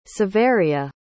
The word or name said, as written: saveria